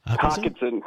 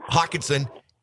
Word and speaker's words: Hockenson: You know, I got to tell you, I've always, like, I hated when the Lions had the tight end, Hockenstein. How do you pronounce his last name? – Hockenson. – Hockenson.